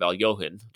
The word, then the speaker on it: Alekhine: Later on in this episode, we see Benny Watts even reference that she attacks like Alekhine, which of course that's the American pronunciation of Alyohin, just for the record.